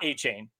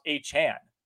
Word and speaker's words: Achane: It is not Achane. He's clarified that he wants it to be pronounced Achane.